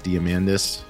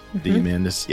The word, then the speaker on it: Diamandis: I believe his name is pronounced Diamandis. Diamandis. Yeah, he's been on our shows before, one of our shows.